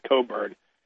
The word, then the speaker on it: Cockburn: The name is spelled Cockburn, but it's pronounced Coburn.